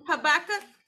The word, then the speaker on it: Habakkuk: There's this scripture in Habakkuk. Did I say it right? – Habakkuk.